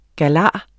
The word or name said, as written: galar